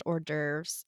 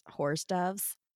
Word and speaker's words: hors d'oeuvres: And she was reading it and she pronounced hors d'oeuvres, whores doves.